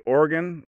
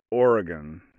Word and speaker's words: Oregon: you can say Oregon, like a heart and a lung, or you can say Oregon, if you want to get fancy. But I recommend just saying Oregon.